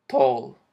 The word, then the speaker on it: tall: Tall Aquí la A no la vamos a pronunciar como A, la vamos a pronunciar más como tall.